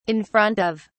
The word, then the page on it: in front of: British English